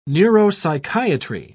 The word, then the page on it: neuropsychiatry: British English